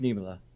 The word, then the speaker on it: Niemelä: Is it Niemelä? – Yeah, we're going to go. Niemelä. Is it Niemelä? – Yeah, it's Niemelä.